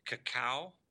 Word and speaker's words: Kakao: Korean messaging app, Kakao.